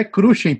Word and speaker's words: Cruxen: Fala do jeito que você quiser, mas é Cruxem, pô.